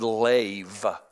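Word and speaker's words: lev (לב): That word in the Hebrew is the word lev. It's actually pronounced like L-A-V-E, even though it's spelled L-E-B.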